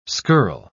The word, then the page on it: skirl: British English